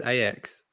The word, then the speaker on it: Ajax: Ajax. – No, you didn't say that. You said Ajax. – Did I? Oh. Yes, you did. Yep. Come on, pronounce it properly. Ajax.